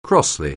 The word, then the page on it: crossly: anglais